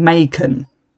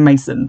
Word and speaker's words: Macon: They call Mason Macon, which may well be how you're meant to pronounce it because it's spelt like Mason Jar but with a C instead of an S. But to my limey opinion, that is still a S sound.